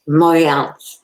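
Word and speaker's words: Montréal: And I think I said it wrong. I think it is Montreal, right? – Montreal. Oui.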